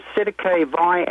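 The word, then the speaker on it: Cestui que vie: But there was something more important happening in the Parliament, and that was a bill called the Sedicavi Act, 1666. Now, if you want me to spell that for you, Vinnie, it's C-E-S-T-U-I, separate word, Q-U-E, and then Vi, V-I-E.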